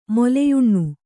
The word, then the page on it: ಮೊಲೆಯುಣ್ಣು: Kannada